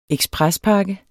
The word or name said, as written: eksprespakke